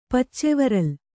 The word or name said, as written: ಪಚ್ಚೆವರಲ್